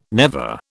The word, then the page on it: never: British English